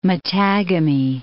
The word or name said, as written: Mattagami